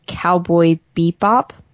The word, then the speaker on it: Cowboy Bebop: Then Paul was asking about if I would watch Cowboy Bebop. – Yes. – Is that how you pronounce it? – Yeah.